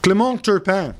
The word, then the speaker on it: Clément Turpin: Clément Turpin.